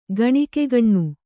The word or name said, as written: ಗಣಿಕೆಗಣ್ಣು